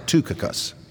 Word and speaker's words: Tychicus: written from Rome unto the Ephesians by actually you pronounce it Tychicus.